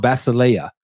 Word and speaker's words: basileia: Now, the word kingdom in John 18:36 is the Greek noun basileia. Basileia is spelled B-A-S-I-L-E-I-A.